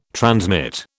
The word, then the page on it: transmit: British English